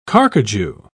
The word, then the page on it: carcajou: British English